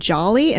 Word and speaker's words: Djali: It's named Jolly and it's spelled D-J-A-L-I.